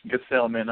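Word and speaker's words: Gsellman: They pronounce Gesellman on the Mets broadcast, G-A, and then Sell in the middle with Man, if anyone's wondering about that.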